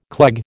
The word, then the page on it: cleg: American English